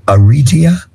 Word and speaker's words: areata: Areata? Area? – Alopecia areata. I knew you'd know. It is an autoimmune. – It makes it sound so pretty, y'all. – I know it did. – Areata.